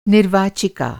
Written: निर्वाचिका

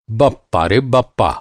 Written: बप्पा

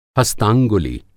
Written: हस्तांगुलि

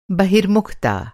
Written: बहिर्मुखता

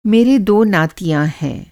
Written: नाती

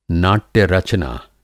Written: नाट्य-रचना

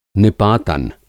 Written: निपातन

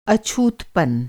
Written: अछूतपन

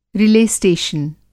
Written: रिले स्टेशन

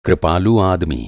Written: कृपालु

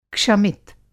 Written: क्षमित

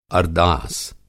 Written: अरदास

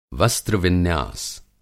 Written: वस्त्र-विन्यास

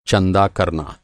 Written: चंदा

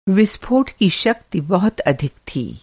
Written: विस्फोट

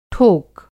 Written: ठोक

